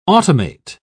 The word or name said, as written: automate